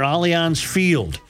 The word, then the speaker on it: Allianz Field: The St. Paul City Council just approved a multi-million dollar redevelopment project near Allianz Field.